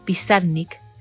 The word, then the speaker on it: Pizarnik: Pero lo castellanizaron y ella lo acentuaba en la segunda sílaba, Pizarnik.